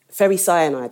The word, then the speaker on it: ferricyanide: It's potassium ferricyanide, that's how you say it.